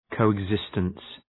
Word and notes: coexistence: ,kəʋıg’zıstəns

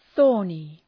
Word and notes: thorny: ‘ɵɔ:rnı

